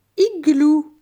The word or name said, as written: Igloo